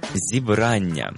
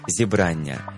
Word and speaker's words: зібрання: Наприклад, церковне зібрання. І увага, прошу запам'ятати правильний наголос. Не зібрання і не зібрання, як можна часто почути, а саме зібрання. Наголос на другому складі.